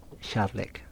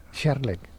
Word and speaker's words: kärlek: Kärlek. – Kärlek.